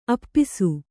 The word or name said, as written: ಆಪೋಸ